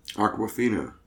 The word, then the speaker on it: Awkwafina: How do you say her name? – Awkwafina. – Like the water.